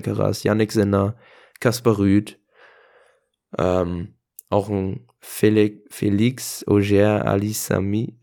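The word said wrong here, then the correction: Félix Auger-Aliassime: Ich kann das aber nicht aussprechen. – Felix Auger Aliassim. Danke. Perfekt.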